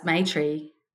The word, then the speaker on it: Maitri: I was seeing a psychologist at the time, and she said to me, do you realise Maitri is a Sanskrit word that means loving kindness? It's spelt differently, M-A-I-T-R-E, but said the same.